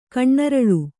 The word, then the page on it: ಕಣ್ಣರಳು: Kannada